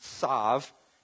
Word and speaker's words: shav: The word for vain, the Greek word is sav, S-H-A-V, pronounced sav, meaning emptiness, nothingness, lightly or casually.